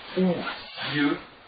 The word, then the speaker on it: Осирис: Они произносили Осирис, по всей видимости, как Усир, а Исиду как Иси.